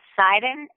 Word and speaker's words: Seiden: My last name is Seiden. It's spelled S as in Sam, E-I-D as in dog, E-N as in Nancy.